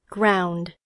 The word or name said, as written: ground